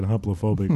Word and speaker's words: hoplophobic: Did I say that right? – Yes, you did. – I said hoplophobic, right.